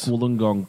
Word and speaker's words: Wollongong: Like Wollongong because it rhymes with pull and bong.